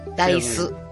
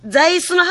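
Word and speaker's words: dice: ダイス。 – あー、違う。ね、もう一回言って。 – ダイス。 – はい、じゃあ、ザイスの発音じゃなくて、ダイス。 – え？ – 発音が。 – ライスの方か。ライス。 – そうそう、ライスの発音で、ダイス。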